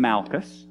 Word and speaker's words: Malchus: It's Kidron and Malchus.